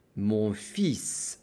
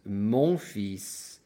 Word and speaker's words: fils: A common mistake is for people to want to pronounce the L because it's spelled F-I-L-S. So you cannot say mon fil. That would be wrong. You have to say mon fils.